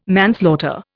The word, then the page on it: manslaughter: Indian